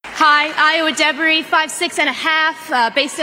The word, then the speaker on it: Ayo Edebiri: Her name is pronounced Ayo Edebiri.